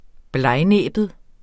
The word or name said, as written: blegnæbbet